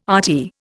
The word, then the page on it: RT: Indian